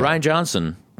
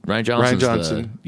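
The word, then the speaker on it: Rian Johnson: Pronounce it Ryan Johnson. Ryan Johnson.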